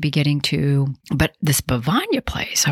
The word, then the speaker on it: Bevagna: You should really go to Bavagna. So finally, I decided to go. and it has become one of my favorite little towns in Italy. So it's spelled B-E-V-A-G-N-A, Bavagna.